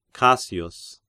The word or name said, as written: Cassius